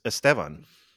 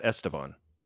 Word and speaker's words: Esteban: It's Esteban, not Esteban.